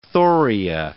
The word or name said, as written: thoria